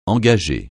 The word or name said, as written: engager